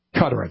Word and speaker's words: Qatar: Qatar, I think is how you pronounce that.